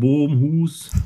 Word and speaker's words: Bromhuis: Wie gesagt, mein Name ist Thorsten Bromhuis, ne, ganz niederländischer Name, aber deutsch ausgesprochen.